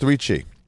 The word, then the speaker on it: 3Chi: It's pronounced 3Chi.